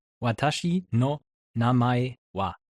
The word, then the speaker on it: Watashi no namae wa: Watashi no namae wa. – Watashi no manai wa. – Er macht's immer noch. Er macht immer noch Watashi no ma. Nein, nicht no ma. Watashi no namae wa.